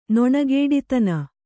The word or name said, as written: ನೊಣಗೇಡಿತನ